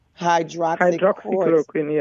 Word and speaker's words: hydroxychloroquine: So we've had a lot of people talk about this hydroquarantine. Is that how you pronounce it? I want to make sure I'm saying it right. – Hydroxychloroquine. – Hydroxychloroquine. – Yes.